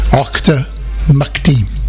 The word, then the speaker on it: Auchtermuchty: I just had an English lesson off Murph, who said the pronunciation is Auchtermuchty.